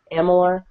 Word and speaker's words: Amalur: I always say it wrong. Amalur.